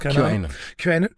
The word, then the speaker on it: QAnon: QAnon hat jetzt behauptet, dass es wieder losgegangen ist, weil es werden jetzt hoch aufgelöste Bilder von Pizzen bei Etsy verkauft.